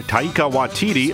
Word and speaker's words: Taika Waititi: It's from director Taika Waititi. Am I saying his name right? I don't know that I am. – Sounds about right, yeah.